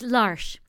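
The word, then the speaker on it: L'Arche: Is it Larch? – It's Larch. Larch. – Larch. Larch. Larch. – Yeah, Larch. – It's actually the French word for the Ark. – The Ark. Larch. Yeah, Larch.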